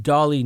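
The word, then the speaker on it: Dahle: It's a Tim Dolly Nissan. And Tim Dolly? It's spelled Dolly. – Yep, so it's D-A-H-L-E.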